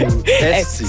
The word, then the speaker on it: Esti: Est-ce que je le dis bien? – Oui, très bien. – C'est Esti ou Esti? – Esti. Ben oui.